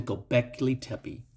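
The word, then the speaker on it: Göbekli Tepe: So in 1995, archaeologists began to excavate a site in southeast Turkey, and it's called the Gobekli Tepe.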